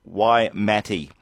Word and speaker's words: Waimate: He said it was a Maori word. We pronounce it Waimate. Waimate is the name of the gorge, the Waimate Gorge, and it ends up at the Forks Pub.